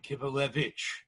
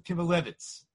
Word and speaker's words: Kivelevitz: As you know, I pronounce the name Kivalevich, like my father does. – Right. – My children pronounce it Kivalevitz, right?